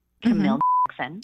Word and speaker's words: Camille Jackson: My name, Camille Jackson. – Mm-hmm. Okay. – And it sounds just like you would spell it.